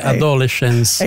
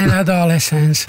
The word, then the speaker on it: Adolescence: Latino sarebbe Adolescence. – È inglese, però... No. – Perché ti viene un po' come se parlassi da anziano. – È Adolescence. – Perché si pronuncia così, si pronuncia così.